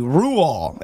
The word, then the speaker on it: Roald: I thought it was Ro. – I did a quick Google and it says the correct pronunciation of his name is actually Ruol, and the D at the end remains silent as the grave.